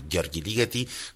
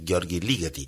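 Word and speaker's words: György Ligeti: Gheorghe Ligeti nació en Hungría, por eso se dice así, Gheorghe Ligeti.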